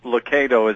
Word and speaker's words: Lucado: I answer to either one, but Lucado is the way we pronounce it.